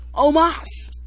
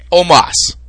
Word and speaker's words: Omos: Omos. – Okay. What am I saying? – That's not how you pronounce it. – Omos.